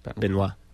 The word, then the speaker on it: Benoit: B-E-N-O-I-T. Benoit.